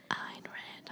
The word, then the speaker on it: Ayn Rand: Sign Rand. – Sign Rand? – Ayn Rand. I'm pronouncing it right this time, I think.